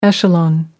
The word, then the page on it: echelon: American English